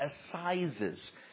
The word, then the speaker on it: assizes: Something called assizes. A-S-S-I-Z-E-S. I think Pastor Miller, that's the right way to say it.